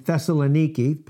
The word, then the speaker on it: Thessaloniki: 2 Thessalonians chapter 2, verse 1, Paul speaking to the church in Thessaloniki, proper way to pronounce it, by the way.